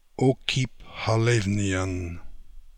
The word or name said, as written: Okipkhalevnian